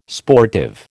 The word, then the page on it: sportive: American English